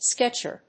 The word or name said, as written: sketcher